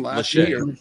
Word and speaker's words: Lachey: And but that catch by Lackey, is that how you say his name? – Lachey.